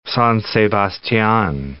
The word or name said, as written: San Sebastian